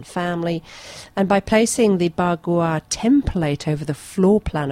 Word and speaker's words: Bagua: The most common being Ba Gua. Do you think I've pronounced that right? Ba Gua. – Sounds about right. – B-A-G-U-A.